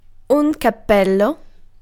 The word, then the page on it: capello: [ka.ˈpel.lo]